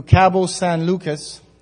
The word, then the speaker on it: Cabo San Lucas: The roads in Mexico from Tijuana, just south of San Diego, to Cabo San Lucas, and that's the correct way you pronounce that one, is 800 kilometers, two lanes, and not much shoulder.